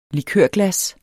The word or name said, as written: likørglas